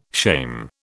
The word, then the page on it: shame: British English